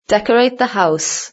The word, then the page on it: decorate the house: British English